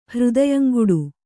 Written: ಹೃದಯಂಗುಡು

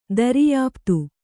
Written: ದರ್ಯಾಃಪ್ತು

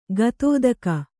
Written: ಗತೋದಕ

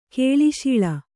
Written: ಕೇಳಿಶೀಳ

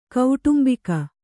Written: ಕುಟುಂಬಿಗ

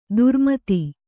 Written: ಧರ್ಮೇತ

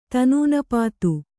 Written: ತನೂನಪಾತು

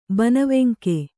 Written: ಬನವೆಂಕೆ

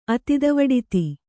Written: ಅತಿದವಡಿತಿ

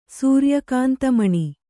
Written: ಸೂರ್ಯಕಾಂತಮಣಿ

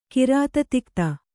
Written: ಕಿರಾತತಿಕ್ತ

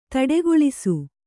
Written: ತಡೆಗೊಳಿಸು

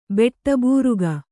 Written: ಬೆಟ್ಟಬೂರುಗ

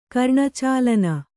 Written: ಕರ್ಣಚಾಲನ

